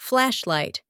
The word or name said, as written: flashlight